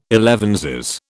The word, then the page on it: elevenses: British English